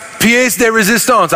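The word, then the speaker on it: pièce de résistance: Chapter 2 is pied de resistance. David, I'm sorry, I just butchered it again. Chapter 2 is, is God's pièce de resistance.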